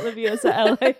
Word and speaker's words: Liviosa: Yeah, you can follow Liv at Liviosa, LiviOSAH.